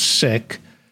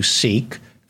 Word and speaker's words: Sikh: It's pronounced Sikh in among the Punjabis, so but we'll call you Sikh for the American audience.